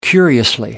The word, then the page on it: curiously: British English